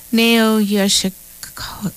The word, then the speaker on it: Nao Yoshioka: Yes, we went from ska, Tokyo ska, to Nao Yoshioka. That's how you say her name.